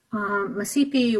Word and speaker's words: Mashpee: Massipee Wampanoag is the way you would say Mashpee Wampanoag traditionally.